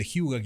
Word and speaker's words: hygge: Si buscan Hyuga, se escribe H-Y-G-G-E, si buscan eso en Google, van a ver el concepto.